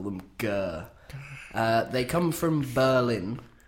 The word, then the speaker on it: Gurr: The act are Gurr. – Gurr, lovely. – I believe that's how you pronounce it. G-U-R-R. – I call them Gurr. – I can't roll my R's.